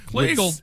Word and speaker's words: Kleagle: Kleagle rhymes with beagle.